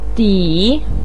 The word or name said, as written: di3